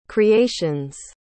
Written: creations

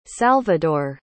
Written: salvador

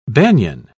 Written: banyan

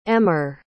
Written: emer

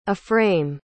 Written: A-frame

